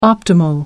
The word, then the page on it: optimal: British English